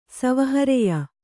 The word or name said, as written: ಸವಹರೆಯ